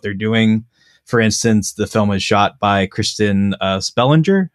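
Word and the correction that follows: Christian Sprenger: I don't know if I'm pronouncing that right, but it's a Christian Springer. – Yeah, Christian Springer,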